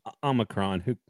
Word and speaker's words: Omicron: And he was talking about, you know, that a few of the Flames players have, how do you pronounce it? The Omicron? Is it the Omicron? – Omicron.